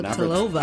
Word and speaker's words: Navratilova: Martina Navratilova.